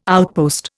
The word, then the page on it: outpost: Indian